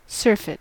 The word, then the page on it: surfeit: American English